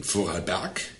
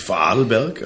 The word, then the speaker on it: Vorarlberg: Lebt mit seiner Frau Irmi im Vorarlberg in Alberschwende. Ist richtig? – Das ist ja wichtig. Vorarlberg, also Betonung auf Arlberg.